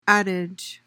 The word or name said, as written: adage